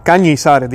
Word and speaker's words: Cañizares: porque también voy a citar hoy al cardenal Cañezares, eh, si no estoy pronunciando el nombre mal, Cardenal Cañizares, disculpen, Cardenal Cañizares, que también habla sobre esto.